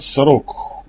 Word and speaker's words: Сорок: «Ваше здоровье, лейтенант Сорок!» — ударение ставлю на первом слоге.